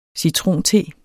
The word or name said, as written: citronte